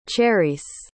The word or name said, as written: cherice